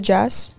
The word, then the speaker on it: Jass: Is it Jass? – Yeah, Jass.